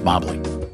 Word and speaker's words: Mobley: Akron had a female basketball player named Mobley, pronounced Mobley. Isaiah Mobley, Evans' brother.